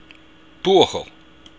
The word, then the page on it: buachaill: Irish